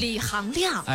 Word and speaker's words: 李行亮: 李行亮。 – 哎，对了啊，我以前一直念李行亮，后来发现是错的啊。